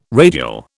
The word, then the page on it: radial: British English